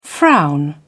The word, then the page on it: frown: British English